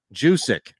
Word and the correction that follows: Juszczyk: Is that how you, that's not how you pronounce it, is it? – I thought so. – Juszczyk.